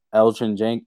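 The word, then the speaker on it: Elgton: Elgin Jenkins. Oh, yeah, okay. The G is silent.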